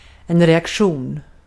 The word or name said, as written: reaktion